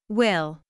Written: will

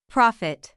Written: profit

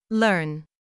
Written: learn

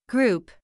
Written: group

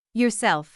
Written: yourself

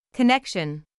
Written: connection

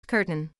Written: curtain

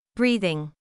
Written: breathing

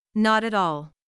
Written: not at all

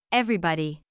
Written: everybody

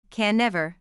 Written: can never

